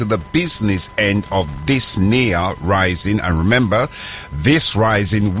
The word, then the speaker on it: Nia: Yeah, we have redubbed it, renamed this day near day, N-I-A, that's how it's spelt, and it simply means purpose.